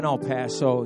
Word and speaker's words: El Paso: Did I say that my dad is in El Paso? Did I say that right? No, is El Paso is the way to pronounce it? He's in El Paso.